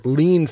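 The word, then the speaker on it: Lien: For Kes fans, or maybe for Jennifer Lean fans, and I looked it up, it is pronounced lean. Like That makes sense. – You lean on a wall.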